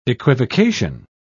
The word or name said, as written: equivocation